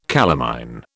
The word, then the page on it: calamine: British English